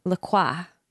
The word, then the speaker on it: La Croix: Well, there's the French pronunciation and then there's like the hipster pronunciation, which is LaCroix, which is wrong.